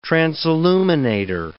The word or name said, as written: transilluminator